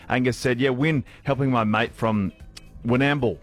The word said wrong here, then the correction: Warrnambool: Ollie, am I saying that right? Winamble. I've got that right. Give me a thumbs up. I think that's good. Winamble. Is that where Angus's mate's from? – I believe it's Warrnambool. – Warrnambool. – Yeah. – Oh, I'm saying it right. Okay, Warrnambool. Thank you, Big Up.